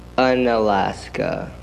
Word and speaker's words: Unalaska: How do you say the name of your city? Because I've read it, but I don't know how to say it. – Unalaska. – It's literally called Unalaska? – Yeah.